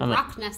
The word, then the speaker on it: Rottnest: B-A-L-D. – Okay. That sounds like it could be that. And Rottnest sounds like the Loch Ness Monster. – It's Rottnest.